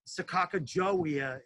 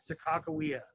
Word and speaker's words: Sakakawea: The most popular thing that people know about is Sakakawea, and people say it wrong all the time. Like people say Sakakajowia, it's not pronounced like that. You talk to the two tribes, the Native Americans out there, they pronounce it Sakakawea. That's how they say it.